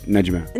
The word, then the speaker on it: Najma: Najma.